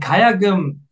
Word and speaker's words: Gayageum: And Gayageum is spelled a little bit weirdly. Like you have G-A-Y-A-G-E-U-M. But it's pronounced very easily. If you just remove the E and U between the G and M, that was very complicated right now, then you'll get it perfectly.